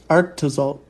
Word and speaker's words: Arctozolt: So we get the Sujulai, Arctozak. Is that how you say it? Zolt? – Arctozolt? – Arctozolt. Okay, Kangaskhan and Drakian. – You had it right.